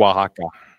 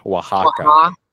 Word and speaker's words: Oaxaca: Was it Oaxaca? Is that correct? – Oaxaca. – Yeah, I'm terrible with pronouncing these things. Oaxaca, is that how you pronounce it? – It's like a Oaxaca. Oaxaca.